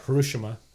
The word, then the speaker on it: Hiroshima: Hiroshima or Hiroshima. That's how you say it.